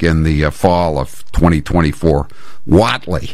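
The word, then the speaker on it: Whatley: Says Watley is pronounced Watley, not Waitley.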